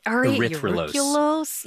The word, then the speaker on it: erythrulose: I've heard of, like, glucose and fructose, but, like, how do you say that? – Erythrulose.